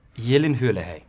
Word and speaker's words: Gyllenhaal: Gyllenhaal.